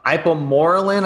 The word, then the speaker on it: Ipamorelin: Ipamorelin, I believe it's pronounced.